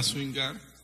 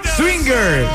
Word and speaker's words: swinger: A swingar. Ah, mi Swinger, dijo, ¿ok? Swinger. S-W. I-N-G-E-R.